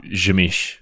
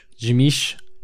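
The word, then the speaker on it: Tzimisce: La Sombra and, how do you say this one? Tzimiske? They basically joined the Sabbat. – Jimish. – Jimish.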